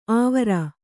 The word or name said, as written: ಆವರ